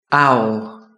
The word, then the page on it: owl: British English